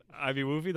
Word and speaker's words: IBWUFY: IBWUFY. IB Woofy, that's how you say it? – That's how we I was like, I don't know. – No, you're right.